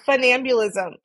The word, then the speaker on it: funambulism: Is that how you say it? – Yeah, funambulism. I mean, the first word is fun.